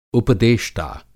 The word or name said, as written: उपदेष्टा